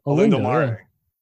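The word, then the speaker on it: Olindo Mare: So a guy like Olinde Mare, I don't even know how to say his freaking name. – Yeah, Olindo, no, I think you nailed it. – Olinde Mare.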